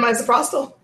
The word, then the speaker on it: misoprostol: We have misoprostol and mifepristone. I'm pronouncing both of those wrong, I'm sure. – Mifepristone and misoprostol.